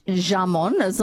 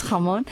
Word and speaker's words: jamón: And it's not jamon. It's spelled jamon with a J, jamon. But it's jamon.